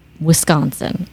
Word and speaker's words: Wisconsin: So the Midwest, it's one Wisconsin, all one. But you, because you're not from the Midwest, split it up.